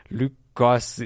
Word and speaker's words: Goss: Luke. – Goss, is that how you say it? – Oh yeah, Luke Goss.